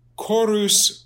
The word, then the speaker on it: chorus: Chorus cori means choir. And obviously we get our word chorus from it, like the men's chorus or, you know, whatever. Notice again, C-H, it's pronounced like a K, and that's a dead giveaway.